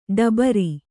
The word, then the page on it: ಟಬರು: ṭabaru